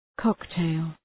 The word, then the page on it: cocktail: ‘kɒk,teıl